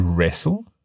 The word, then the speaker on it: wrestle: W-R-I-T-E, another silent W. – It's also interesting to point out that in the word wrestle, we don't really pronounce the T.